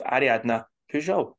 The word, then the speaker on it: Ariadna Pujol: On this show, we are joined by one of the newest gladiators to the WBBL roster, with Ariadna Pujol joining the show.